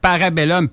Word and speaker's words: Parabellum: Parabellum, P-A-R-A-B-E-L-L-U. Attends je vais recommencer. C'est du latin, ça? Bien sûr, j'adore le latin. Parabellum, comme ça se prononce, P-A-R-A-B-E-2-L-U-M, ça veut dire en fin de compte, si tu veux la paix, prépare la guerre.